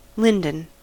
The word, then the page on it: linden: American English